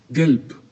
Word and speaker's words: gelb: Gelb. – Aunque al final tiene una B, esa B se pronuncia más fuerte que el español mexicano. Decimos gelb, como si fuera una P suavecita.